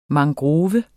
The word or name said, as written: mangrove